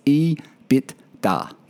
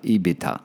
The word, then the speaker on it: EBITDA: It's EBITDA. There you go, Bernard. EBITDA.